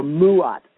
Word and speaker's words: Ma'at: It was the law of Ma'at, or Mu'at is actually a better pronunciation of what it is.